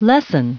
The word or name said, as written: lessen